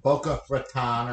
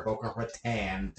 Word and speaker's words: Boca Raton: Anyway, so we are in Boca Raton or Boca Raton, depending on how Floridian you are.